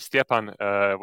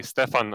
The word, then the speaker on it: Stjepan: But in any case, for my name is Stjepan, which Chad pronounced so nicely. But for those of you who have trouble with that, you can just say Stefan or a version of that. It's completely fine.